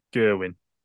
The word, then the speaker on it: Gerwyn: Good pronunciation of Gerwyn.